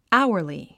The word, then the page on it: hourly: ǽuərli; アウアリィ